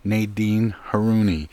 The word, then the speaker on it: Nadine Haruni: Here to talk about that is the book's author, creator of the Frida the Frog book series, Nadine Haruni.